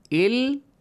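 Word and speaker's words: إلا الذين: And quickly join it to the lam. Illa-ladhina. So do not recite it as illa-ladhina. This is wrong. Illa-ladhina. So hamzatul wasl, skipped. Short vowel will be pronounced. No long vowel, okay? It has a long vowel, illa, but no longer illa. Illa-ladhina.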